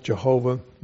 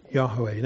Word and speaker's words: Yahweh: at one time thought to be pronounced Jehovah, more likely pronounced Yahweh.